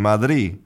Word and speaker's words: Madrid: But when they say the capital of Spain, sometimes that final D is just not pronounced. Madrid is quite a common Spanish pronunciation, Madrid. And so the D is gone completely. But Spanish speakers cannot do that in English because we have too much vocabulary that depends on that final consonant.